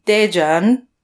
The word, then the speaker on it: Daejeon: Yeah, so you mentioned that you were in Daejeon.